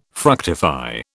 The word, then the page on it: fructify: British English